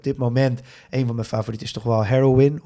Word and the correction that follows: Heroïne: Of eigenlijk moet je het uitspreken als Heroïne.